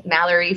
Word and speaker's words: Mallory: And my personal Instagram is my full name at Cassidy Nash Davis, N-A-S-H Davis. – And mine's my full name at Mallory Fusella, M-A-L-L-O-R-Y. – She loves to spell it. – F-U-C-C-E-L-L-A.